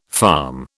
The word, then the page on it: farm: British English